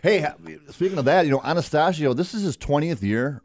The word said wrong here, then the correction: Attanasio: Attanasio. – Attanasio. I'm sorry, I pronounced it wrong.